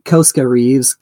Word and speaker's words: Koska Reeves: Costas Reeves, is I saying that right? Koska Reeves comes in and saves the child.